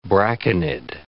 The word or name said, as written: braconid